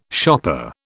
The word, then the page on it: shopper: British English